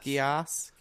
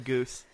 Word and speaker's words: Geass: Watched the anime Code Geass, I think it's pronounced. – Geass. Not Code Goose.